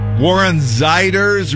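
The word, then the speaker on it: Warren Zeiders: Warren Ziders, remember it rhymes with sliders.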